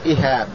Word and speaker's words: إهاب: نعم طبعا الإهاب هذا هكذا تسمعونه نطقه الشيخ، نقول إهاب بكسر أوله فتح الهاء، يعني مثل كتاب،